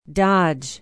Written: Dodge

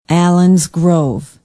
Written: allens grove

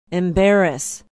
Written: embarrass

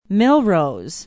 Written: melrose

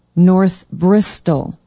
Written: north bristol

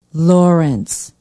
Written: Lawrence

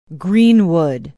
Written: greenwood

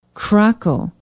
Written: krakow

